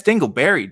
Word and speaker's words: Dingleberry: Dinglebury Jackson. – Okay, so you said it again. You said Dinglebury. Dinglebury. What's up with that? That's Dingleberry, dude.